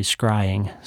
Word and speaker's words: scrying: Also, one of the engineers picked up that I mispronounced screeing. I said it screeing, and it's actually scrying, so I had to learn how to say that word too.